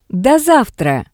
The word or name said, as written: Do savtra